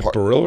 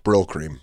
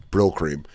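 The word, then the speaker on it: Brylcreem: Is it Barilla or Brill? – Brill cream. – Brill cream? – Yeah, I've said it wrong. Brill cream.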